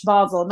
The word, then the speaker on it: Basel: You have to say it like a douche. Basel. Not basil, like your spice. Basel.